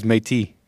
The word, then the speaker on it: Métis: Getting back to this Métis, no S sound. – Someone in chat said you pronounced the S. I forget. I swear it was Métis. Métis, yeah, not S.